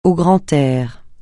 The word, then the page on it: au grand air: francais